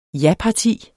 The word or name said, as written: japarti